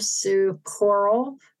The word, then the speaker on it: Sue Corl: Sue Corl, which is spelled without an A, C-O-R-L.